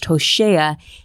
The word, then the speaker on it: Hosea: Hosea can also be pronounced Hoshea and carries the same root word for the Hebrew word of salvation.